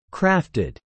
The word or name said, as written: crafted